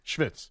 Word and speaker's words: schvitz: How do you say it again? Schwitz? – Schwitz. – Schwitz? – Schwitz. – No K, right? – No K. – Just schwitz. – Just schwitz. – So S-C-H-V-I-T-Z? – Yes.